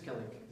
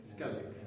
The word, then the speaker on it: Skellig: Hoe moet dat goed uitspreken? – Skellig. – Skellig. Yes. Ja.